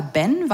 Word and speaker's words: Behn: Afra Behn, gesprochen eigentlich eher Ben, weil obwohl der Ehemann, der ihr zu diesem Namen verholfen hat, vermutlich deutschstämmig war, hat sie in England gelebt und dort wird Behn Ben ausgesprochen.